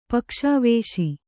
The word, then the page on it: ಪಕ್ಷವಾಸ: pakṣa vāsa